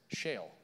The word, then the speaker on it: Sheol: It's spelled S-H-E-O-L, pronounced Sheol, the realm of the dead.